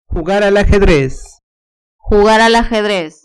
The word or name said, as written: jugar al ajedrez